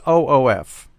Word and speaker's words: OOF: This is OOF. You still pronounce it oof. OOF is the basically the key letters.